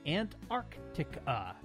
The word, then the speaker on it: Antarctica: I always pronounce the continent Antarctica. Most others seem to leave out the K sounds in the second syllable.